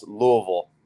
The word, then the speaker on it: Louisville: So it's pronounced Louisville. – Louisville. I butchered it. – Butter in your mouth. Louisville. – Louisville. Okay. – Louisville. Yeah. You know, it's not Louis.